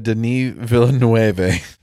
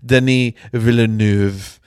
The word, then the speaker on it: Villeneuve: I have to say, for those who listened to the Dune episode, I pronounced the director's name so incredibly wrong. I said Denis Villeneuve. The U and the E are the opposite way. He is French Canadian. It is Denis Villeneuve.